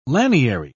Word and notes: laniary: British English

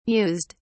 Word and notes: mused: British English

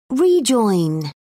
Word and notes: rejoin: British English

